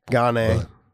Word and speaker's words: Gane: Cyril, how do you say his last name? Gain or Gone? Gan? – Well, there's so many people saying Gane. – Gane. But okay. Look, look, Cyril Gane.